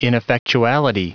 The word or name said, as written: ineffectuality